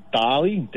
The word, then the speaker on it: Dale: So, nickname's Dali, D-A-L-E, but they pronounce it Dali.